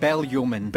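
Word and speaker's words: Bellyeoman: Is it Bell-Yeoman? – It's Bell-Yeoman. – Bell-Yeoman. – You sure? – Yes. – I think it's Bell-Yeoman. – Bell-Yeoman Primary School in Dunfermline. If you go there, you know how to say it.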